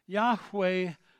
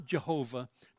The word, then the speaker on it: Yahweh: The man's name means Yahweh saves. Yahweh is God's Old Testament name. His name is not Jehovah. There is no J or V in the Hebrew alphabet. They could not have pronounced it, let alone spelled it.